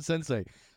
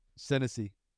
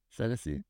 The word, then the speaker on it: Senesi: we're gonna call him Sensei. Sensei scored. – Wait, and now how do you actually say it? – Senesi. – Senesi.